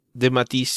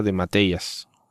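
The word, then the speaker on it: de Matteis: Pero bueno, ¿por qué le pusieron John Mark en vez de Jean-Marc de Matisse? Si es de Matisse, no lo sé. Pero en fin, y nunca supe que se pronunciaba de Mateias, algo así.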